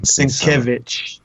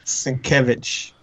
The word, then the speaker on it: Sienkiewicz: It reminds me a little bit of like Bill Sienkiewicz, kind of Sienkiewicz, or however you say his last name. – Sienkiewicz. – Yeah, Sienkiewicz. – Sienkiewicz. – Yeah, you're right.